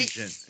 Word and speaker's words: Nugent: Nugent is right. Nugent.